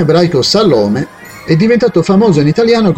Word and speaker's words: Salome: Un confronto fra due versetti dei Vangeli, Matteo 27,56 e Marco 15,40, suggeriscono che l'unica persona chiamata Salome nella Bibbia, con l'accento sulla penultima sillaba, era la madre dei figli di Zebedeo, gli apostoli Giacomo e Giovanni.